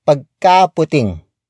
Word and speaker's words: pagkaputing: Again, you could elongate the ka syllable for added exaggeration, like pagkaputing.